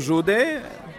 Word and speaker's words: Jude: And I'm here reporting from the London Film Festival, the 60th edition, with Radu Jude, the Romanian director of one of the best films of the festival this year, Scarred Heart. – I'm Radu Jude, or Jude, if you want the British pronunciation, and I'm a Romanian filmmaker, and I'm in London for the third time.